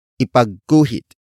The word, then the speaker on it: ipagguhit: Word for word it's ipagguhit, mo, ako, nang, bulaklak. Ipagguhit, mo, ako, nang, bulaklak. Ipagguhit, mo, ako, nang, bulaklak. The first word is ipagguhit. Ipagguhit. Ipagguhit. Ipagguhit is spelled as I-P-A-G-G-U-H-I-T.